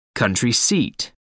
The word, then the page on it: country seat: American English